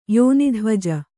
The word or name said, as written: ಯೋನಿಧ್ವಜ